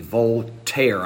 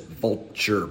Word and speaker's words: Voltaire: In 1728, there was a French skeptic by the name of Voltaire, V-O-L-T-A-I-R-E, Voltaire. I always want to pronounce it vulture, but that's not it. It's Voltaire, V-O-L-T-A-I-R-E.